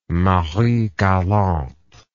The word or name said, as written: Marie Galante